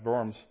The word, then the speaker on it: Worms: I know it looks like Diet of Worms in English, but it's pronounced Worms. It's a town in Germany.